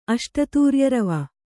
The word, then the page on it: ಅಷ್ಟತೂರ್ಯರವ: Kannada